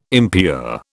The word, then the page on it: impure: British English